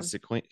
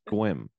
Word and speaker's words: Sequim: You can't call it Sequim. You can't call it Sequim, even though it's spelled Sequim. It's pronounced Squim.